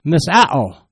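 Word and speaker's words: misao (μισέω): Watchman comment, that word hate in the Greek is pronounced misao, meaning to pursue with hatred.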